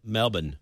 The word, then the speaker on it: Melbourne: When I asked him, he said it, Canberra Grammar, because it's Canberra. You don't say the syllables. Like Melbourne is Melbourne.